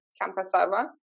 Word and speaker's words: Camka Sarvan: So really go on Instagram um, slash my name Camka Sarvan Mm-hmm.